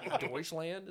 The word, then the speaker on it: Deutschland: That's why turtles are extinct in Deutschland.